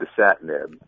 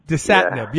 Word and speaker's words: Dasatinib: The quercetin and desnebulib. How do you pronounce it? – Quercetin and Dasatinib. – Dasatinib, yeah.